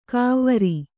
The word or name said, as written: ಕವ್ವರಿ